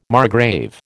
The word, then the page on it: margrave: American English